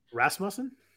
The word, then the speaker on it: Rasmussen: I can't pronounce his name, even though it's a very simple, common name. – Rasmussen? – Yeah, Rasmussen. I guess that's it. If I go Muss instead of Muse, then I'm good.